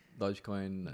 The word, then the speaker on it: Dogecoin: Dogecoin.